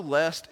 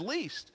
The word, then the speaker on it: lest: I know lest is the correct pronunciation, but I was raised in East Tennessee and I thought we all just said least.